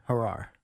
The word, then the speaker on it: Gerard: I believe it's Gerard. – Gerard? – I listened to Howie Rose's call. – Oh, that's interesting. – Soft J, Gerard. – All right, because I was listening to I was watching the Mets TV broadcast. – Gary Cohen went with the hard J.